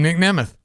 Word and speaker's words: Nemeth: Nick Nemeth. That's actually how you say it, actually. That's his name, Nick Nemeth.